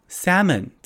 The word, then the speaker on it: salmon: Salmon 这个这个 L 呢是不发音的哦，所以你就当做那个 L 不存在，念 salmon salmon。